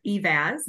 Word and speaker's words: Eivaz: And I am really, really encouraged and excited today that I have Jennifer Eivaz.